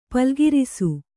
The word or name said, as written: ಪಲ್ಗಿರಿಸು